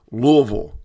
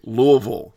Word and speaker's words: Louisville: But this past weekend, if you saw on social media, we were in Louisville. I was told three times, got to say it properly, Louisville, Kentucky. – Louisville.